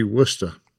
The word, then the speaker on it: Worcester: That'll be Worcester. – Worcester, excuse me. They pronounce it like that in South Africa too, huh? – Well, pretty much everywhere. – They copy the British pronunciation.